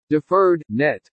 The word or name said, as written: DEFERRED NET